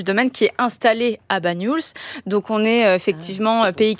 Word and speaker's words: Bagnols: Comment on le prononce? – Bagnols. – Bagnols. – Oui, ouais. C'est ça.